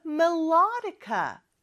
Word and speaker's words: melodica: I play the melodica. 또 이음절의 강세가 있죠. 자, 따라해 볼까요? melodica, melodica. I play the melodica.